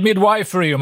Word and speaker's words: Midwifery: Susanne Åhlund, barnmorska och Chief Midwifery Officer på Socialstyrelsen. Eller Midwifery om man vill använda det lite mer konservativa uttalet.